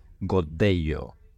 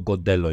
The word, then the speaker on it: Godello: Um, so yeah, an area known for Mencía, one of your favorite grapes and wines, but they also have a grape variety called Godello, or Godello if you don't pronounce the double L and it's a great, great variety.